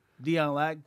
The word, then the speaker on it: Dion Lack: Dion Lack. D-I-O-N, not D-E-O-N. – Say it right. – Lack. That is the name.